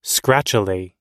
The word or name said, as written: scratchily